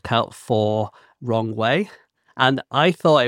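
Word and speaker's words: Rong Wei (荣威): But if you pronounce it in pinyin, then you say it Rong Wei. It sounds almost exactly the same as Rong Wei in English. – Rong Wei.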